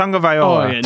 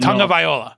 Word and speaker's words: Tagovailoa: It's Tagovailoa. – Tagovailoa.